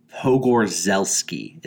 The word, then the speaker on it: Pogorzelski: So Ari Aster used his longtime cinematography partner, Pavel Pogorzelski, is how you pronounce it.